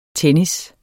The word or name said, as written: tennis